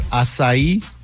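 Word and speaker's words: açaí: Oh, and it's acai? – Yes. – Wow. – And people have corrected me. Have they corrected you over? – Oh, yeah, all the time. – So I say it right now, but only because I've been humiliated.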